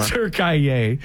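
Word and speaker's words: Türkiye: Literally, there was like a split second left in, how do you say it, Turkiye? It's not Turkey anymore. – Turkiye.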